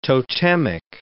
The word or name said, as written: totemic